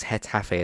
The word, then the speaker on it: Getafe: Um, so he's been loaned out to I think it's Hetafe is how you say the actual name.